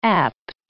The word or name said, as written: apt